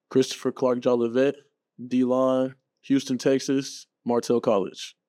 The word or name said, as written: Christopher Clark-Jolivet